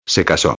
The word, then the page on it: se caso: espanhol